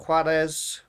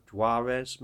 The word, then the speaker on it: Juarez: And Call of Juarez, which I pronounce in a Spanish kind of way, English people, Call of Juarez maybe.